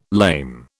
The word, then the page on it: lame: British English